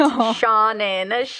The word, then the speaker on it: Seanan: And before we end, I have to correct myself because I always say Seanan McGuire's name wrong. It's Seanan. Drilling it into my head.